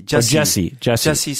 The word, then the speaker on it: Jussie: Is it Justin or Jussie? – Jussie Smollett.